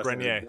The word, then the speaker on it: Grenier: And he said, at one point, Adrian, how do you say his last Grenier.